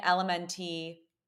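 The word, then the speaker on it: LMNT: And Elementi, or it's pronounced Element, but I'm just spelling out for you. It's L M N T.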